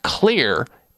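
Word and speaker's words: Xlear: It's called Clear, and Clear is spelled with an X, X-L-E-A-R.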